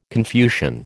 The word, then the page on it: Confucian: American English